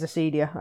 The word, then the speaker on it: Acedia: But I'm assuming I would want to go with a soft C. – Acedia. – Yeah, it is Acedia. I just checked.